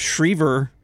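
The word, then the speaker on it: Schriever: Shriver rhymes with Weaver.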